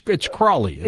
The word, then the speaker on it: Crowley: It's Crowley, isn't it? – It's Crowley. At least he corrected people and said it rhymes with holy.